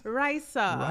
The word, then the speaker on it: Raisa: My name is Raisa. – Wait, yes. How you say your name? – My name is Raisa.